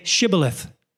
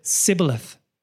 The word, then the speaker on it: Shibboleth: When he said, No, they said to him, Then say Shibboleth. And he said, Sibboleth. Shibboleth. Sibboleth. For he could not pronounce it right.